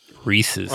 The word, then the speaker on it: rhesus: Did you know there's a monkey called the rhesus monkey? – Reese's. – Like Reese's Pieces? – Yeah. No, it's spelled differently, but it's pronounced.